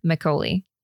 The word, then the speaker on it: Makgeolli: So I found a like Korean, like, let's learn how to say it on YouTube. – Okay. – And it sounded like Makgeolli.